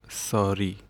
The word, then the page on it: ساری: [sɒːˈɾiː]; Persian